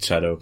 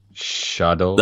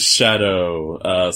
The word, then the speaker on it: Chado: This is a French game, and how do you say CH in French? – Shadow. – That's right. His name is Shadow.